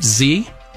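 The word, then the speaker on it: ze: You know, there's a movement to use the letters Z-E, pronounced Z, like, which is an international genderless pronoun.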